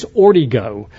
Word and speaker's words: Ortego: My name is Phil Ortego. A lot of people try to pronounce it Ortego or Ortega or something like that, but it's actually a Cajun name. It's Ortigo.